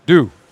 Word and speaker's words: doux: Now, the thing, deux, much better. – Deux. Got to make sure, just like the morning dew. Same thing. And it's spelled D-O-U-X. – Correct.